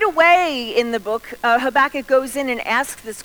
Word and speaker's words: Habakkuk: But other of us say the name Habakkuk. Habakkuk, that's the way to pronounce his name.